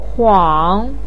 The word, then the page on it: huang: 3